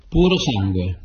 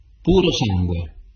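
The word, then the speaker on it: Purosangue: Puro sangue. Puro sangue. – Puro sangue. – Wanna play the game? – Yeah. – There we go. – Puro sangue.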